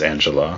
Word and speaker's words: Anjelah: I know it's Angela. Her name is spelled stupidly. – Yes. It was spelled differently and stupidly. – Yes. A-N-J-E-L-A-H.